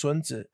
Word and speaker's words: Sun Tzu: I'm also going to cover a very important analysis that stems from what people in America call Sun Tzu, art of war, but it's actually in Chinese, it's pronounced Sun Tzu.